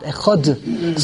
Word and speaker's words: echad: So the answer is we don't pronounce the dalet correctly. The real way it's echad. It's a th because there is no dot in the dalet. We read the dalet as if there's a dot, echod.